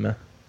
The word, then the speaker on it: Mimi: It's pronounced meme. – Yeah. E-M-M-E-M-E.